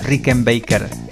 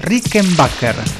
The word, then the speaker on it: Rickenbacker: Tudo que sobe e desce, Juan, nuestro diseñador, me sopla que no es Rickenbacker la guitarra, es Rickenbacker. Rickenbacker.